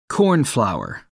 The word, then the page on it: cornflour: American English